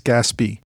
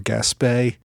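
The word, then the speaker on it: Gaspee: And just at the outset, I'll note that there is some debate whether to pronounce it Gaspé or Gaspé. I'm going with Gaspé, but if you care, don't take that as the final authoritative answer on the pronunciation.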